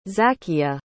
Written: Zakia

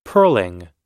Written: pearling

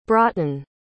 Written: broughton

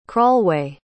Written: crawlway

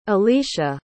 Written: alycia

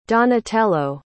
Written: donatello

